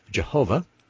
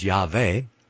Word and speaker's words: Yahweh: Christians erroneously pronounce Yahweh as Jehovah, and the original pronunciation, he says, was Yahweh. But the main point here is that there is no W.